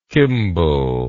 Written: kimball

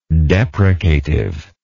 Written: deprecatively

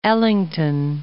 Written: Ellington

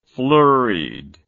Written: flurried

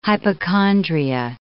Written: hypochondria

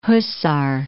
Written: Huszar

